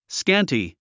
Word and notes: scanty: skǽnti; スキャンティ

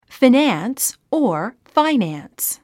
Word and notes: finance: finǽns; フィナァンス

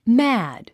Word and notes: mad: mǽd; マァド

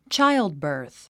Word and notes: childbirth: tʃáildbə̀ːrθ; チャイルドバース

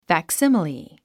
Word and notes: facsimile: fæksíməli; ファクシミリ

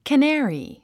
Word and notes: canary: kəné ə ri; カナァリィ

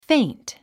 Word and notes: faint: féint; フェイント

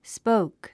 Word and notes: spoke: spóuk; スポーク

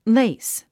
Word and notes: lace: léis; レイス

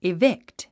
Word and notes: evict: ivíkt; イビクト

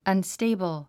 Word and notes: unstable: ʌnstéibl; アンステイブル

